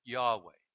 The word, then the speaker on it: Yahweh: We pronounce it sometimes Yahweh.